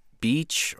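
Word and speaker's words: Bécs: It's called Beach or Beach, something like that. It's spelled B-E-C-S, but the pronunciation guide they gave me is B-A-E-E-T-C-H. It's Hungarian. It's a Hungarian word that means Vienna.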